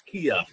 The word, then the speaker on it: Kea: And forgive me if I mispronounced your last name. Is it Kea or Kea? – It's Kea.